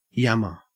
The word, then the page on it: yammer: Australian English